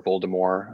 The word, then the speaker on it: Voldemort: Yeah, so for Azkaban, I think I like the Voldemort storyline, or Voldemort, I guess I should say, as it's just supposed to be pronounced.